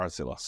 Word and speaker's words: RTILA: Maybe you can tell something about your current company, uh, the Artzilla. Or did I did I pronounce That's right? That's right. Yeah. Artzilla.